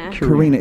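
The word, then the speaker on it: Quirina: Karina. – It's pronounced like Karina, the normal Russian name, correct? – Right.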